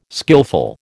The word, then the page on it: skillful: American English